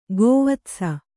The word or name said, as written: ಗೋವತ್ಸ